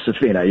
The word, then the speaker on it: Safina: Just like it sounds, Safina, yes, S-A-F-I-N-A.